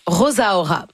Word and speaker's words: Rosaura: Premier EP assez court, à peine 18 minutes, 6 chansons de Talia Rosaura, où elle prononce Rosaura. Donc, j'imagine que c'est comme ça qu'il faut le dire. – Hein? Mais c'est comme Rosalia. – C'est ça. Ouais. Mais R-O-S-A-U-R-A.